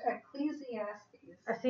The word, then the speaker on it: Ecclesiastes: Ecclesiastes. – See, I always say that wrong. – Ecclesiastes, yes.